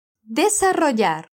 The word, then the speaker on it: desarrollar: Então, bora repetir sílaba por sílaba desse verbo. De, sa, ro, yar. Desarrollar.